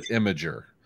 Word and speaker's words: Imgur: Is this pronounced Imgur? Imgur, how do we do that? – I call it Imgur, but okay.